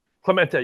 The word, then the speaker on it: Clemente: Is that how it's pronounced, Clemente? – Clemente, yes.